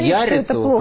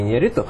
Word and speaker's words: Иньярриту: которое, безусловно, является фильмом Иньярриту, правильно говорится, он... – Иньярриту, а не Иньярриту.